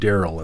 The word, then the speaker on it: Darrel: I spell Daryl D-A-R-R-E-L. Almost everybody else spells it with two L's, or they spell it with a Y, or whatever.